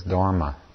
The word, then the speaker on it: Dharma: In Pali, it's pronounced Dhamma, and in Sanskrit, it's Dharma.